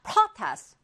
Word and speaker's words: protest: protest or protest. 둘다 발음이 가능하고요. 강세가 어디에 오느냐에 따라서 동사냐 명사냐가 결정이 됩니다.